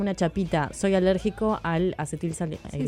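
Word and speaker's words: acetilsalicílico: En el año 1839, está, en el año 1897, Felix Hoffmann descubre el ácido acelitásico, conocido como la aspirina, y es un fármaco de la familia de los salicilatos. – Se utiliza... Es acetil salicílico. – Sí, lo dije mal, ¿vio?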